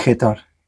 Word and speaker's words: Gettor: José Luis Getor. Lo dije bien, ¿no? Porque muchos dicen Getor. – Getor, Getor, así como suena, como se escribe, e G-E-T-O-R, Getor.